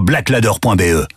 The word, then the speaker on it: Blaklader: Va vite voir sur blackladder.be. Black Ladder, ça s'écrit BLAKLADER.